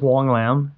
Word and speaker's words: Hoang: Yeah, so my name is Wong Lam, and it's spelled H-O-A-N-G, but it's pronounced W-O-N-G, and you can call me anything you want, just don't call me maybe.